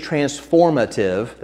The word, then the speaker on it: transformative: If this is transformative, I think I put an extra syllable on that word. If this is transformative, there we go, it's not going to transform you if you're not letting it impact your life, right?